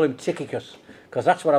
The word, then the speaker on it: Tychicus: Okay, so now then, there's a big dispute about how you pronounce this fella's name. Tychicus or Tychicus. Tychicus, I quite like it.